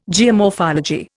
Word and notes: geomorphology: Indian

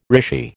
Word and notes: rishi: American English

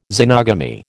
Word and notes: xenogamy: American English

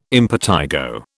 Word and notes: impetigo: British English

